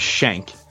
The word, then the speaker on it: Schenck: You pronounced my last name as Schenck, like a prison shank.